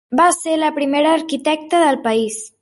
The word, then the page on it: arquitecta: catala